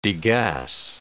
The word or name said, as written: degas